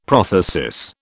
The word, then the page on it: prothesis: British English